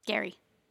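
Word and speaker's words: Gerry: Gary. – Gary. But it's spelled weird. It's G-E-R-R-Y.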